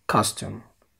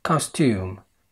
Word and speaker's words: costume: طيب، خد بالك ان الكلمة دي ليها طريقتين في النطق. بالانجليزي الامريكي ليها طريقتين، بالانجليزي البريطاني ليها نفس الطريقتين. تقدر تقولها سريعة زي كده costume costume، او تقولها ممطوطة شوية وتقول costume costume. في ناس بتفضل تقولها ممطوطة costume، وفي ناس بتفضل تقولها سريعة costume. فالاتنين صح، انت تقدر تنطقها بالطريقة اللي تعجبك.